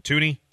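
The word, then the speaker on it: Thuney: Well, how do you say this guy's name? The guard from Kansas City, Joe Tunney? – Tooney.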